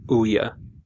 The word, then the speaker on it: Ouya: So instead, you're telling me to buy an Ouya you should buy.